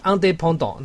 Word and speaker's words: indépendant: 法语很多字有一个很特别的地方，字尾是不发音，你看它的独立 indépendant，它这个字就是不发的，而且它那个 e 上面还长一根毛，后面是 ant 结尾，超酷的。